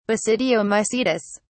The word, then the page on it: basidiomycetes: British English